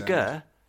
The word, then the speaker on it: GIF: Either way, it's graphical, which is a hard G. – It's a GIF.